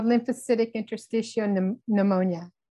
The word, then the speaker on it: lymphocytic interstitial pneumonia: It's called lymphocytic interstitial pneumonia.